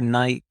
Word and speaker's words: Nike: I remember Nike, or Nike if you're in the US, and in the UK you pronounce it Nike.